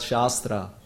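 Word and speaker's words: Shastra (शास्त्र): Shastra with the long A is called Shastra. And if one speaks about Shastra, then it is scripture. And if it is Shastra with short A, Shastra, then it is weapon.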